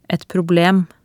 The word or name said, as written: et problem